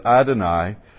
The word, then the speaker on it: YHWH: Therefore, when they encountered the noun YHWH in Scripture, they would pronounce it as Adonai, which means Lord.